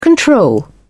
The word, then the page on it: control: British English